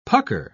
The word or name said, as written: pucker